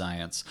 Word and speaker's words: Zients: His replacement is Biden's former coronavirus response coordinator, Jeffrey Zients. And trust me, I looked it up. It's pronounced Zients.